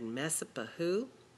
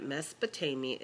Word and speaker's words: Mesopotamia: When we first started Mesopotamia, you looked at me, you looked at the word, and you said, Mesopahu? But now you know how to pronounce it, and you know that Mesopotamia is the Greek word that means the land between the rivers.